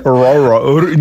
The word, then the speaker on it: rural: Rural. Nie rural. – What's wrong with pisze się rural, a wymawia się rural.